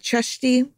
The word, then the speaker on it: Csejthe: I'm going with Cesti because it's supposedly closest to the original Hungarian pronunciation, but it depends if you're going with the Slovakian spelling / pronunciation or the Hungarian. Long story short, I'm following the C-S-E-J-T-H-E version Kraft cites in her book.